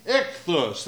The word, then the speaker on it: Ichthus: Anybody can pronounce the name of that fish? Ichthus, that's right.